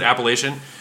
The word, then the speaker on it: Appalachian: If that's where the people who live in the Appalachian Mountains, if they pronounce it Appalachian, yes, then that is then that's the correct pronunciation.